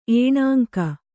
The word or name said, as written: ಏಣಾಂಕ